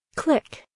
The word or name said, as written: click